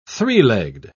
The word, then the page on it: three-legged: British English